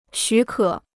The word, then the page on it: 许可: xǔ kě